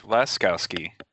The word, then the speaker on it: Laskowski: My name is Rich Laskowski.